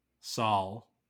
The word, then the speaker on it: Saul: Saul, which rhymes with ball.